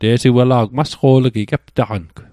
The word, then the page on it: masqo'laji: Mi'gmaq